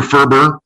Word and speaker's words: Ferber: Fortunately for me, Ferber, Dr. Ferber, rhymes with Gerber.